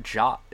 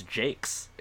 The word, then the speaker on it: Jacques: It's not Jacques. It's Jakes. It's Brian Jakes.